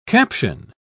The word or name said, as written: caption